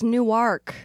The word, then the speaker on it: Newark: Yeah, but you said it wrong. – I said it wrong? – It's Newark. – Newark? – Yep, we pronounce it. – What's going on down there? – It's spelled the exact same way. – Newark? – As Newark, New Jersey.